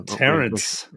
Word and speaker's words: Tarrants: The Tarrants. – Yes. – Rhymes with parents.